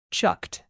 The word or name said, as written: chucked